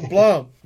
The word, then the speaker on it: Blum: Then Thom Bloom or Blum? – Uh, either one. I, I, pronounce it Blum. – Okay. – Like okay. Plum.